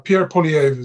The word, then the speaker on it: Poilievre: Pierre Poilievre is how he pronounces it.